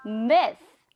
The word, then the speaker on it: myth: 영어로는 myth. 그렇죠. 끝에 발음이 th. Myth, myth, myth.